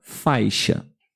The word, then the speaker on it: faixa: Então, a letra X tem o um som chiado, ok? Ok. Como, por exemplo, a palavra faixa. Faixa. Faixa é um ditongo, tem duas vogais na primeira sílaba. – Sim. – Então, se você ver essa palavra, você sabe que ela tem o som de X, ok?